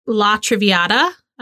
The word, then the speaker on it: La Traviata: So the opera that they go to is La Traviata. I don't know if I'm saying that correctly. – Sounded perfect.